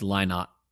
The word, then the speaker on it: Lynott: It's Lynott. Lynott. – Lynott. – Philip Paris Lynott. – Yeah, man. – Lynott.